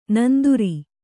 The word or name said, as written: ನಂದುರಿ